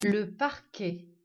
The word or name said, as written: Parquet